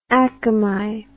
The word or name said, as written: Akamai